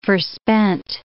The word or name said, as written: forspent